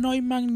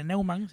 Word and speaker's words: Neumann: Noel, Neumann, Newman, Neumann, como le quieran decir, eh, da igual españoles, díganle Neumann.